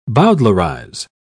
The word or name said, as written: bowdlerize